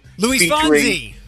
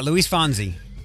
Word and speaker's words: Luis Fonsi: Luis Fonsi. – I always say it wrong. Luis Fonsi? – Yeah, Luis Fonsi.